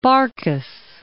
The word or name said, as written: Barcas